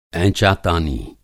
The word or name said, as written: ऐंचातानी